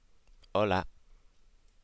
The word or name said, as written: hola